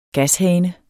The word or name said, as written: gashane